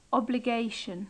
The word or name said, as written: obligation